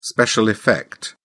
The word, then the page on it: special effect: British English